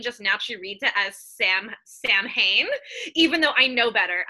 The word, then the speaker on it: Samhain: It's spelled Samhain. It looks like Samhain, but it's Samhain.